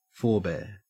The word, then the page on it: forbear: Australian English